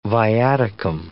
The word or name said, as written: viaticum